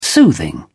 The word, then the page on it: soothing: British English